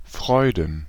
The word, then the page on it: Freuden: /ˈfʀɔɪ̯dn̩/